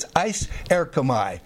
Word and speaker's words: eiserchomai: It's eiserchomai, E-I-S-E-R-C-H-O-M-A-I. Now, if I had an overhead, I'd write it up here. The first three letters, ice, or ace, some pronounce it, E-I-S, means into or unto.